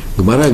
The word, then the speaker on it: Гмара: Гмара или Гемара по- по-русски есть. И так, так говорят, есть два варианта произношения. И там же,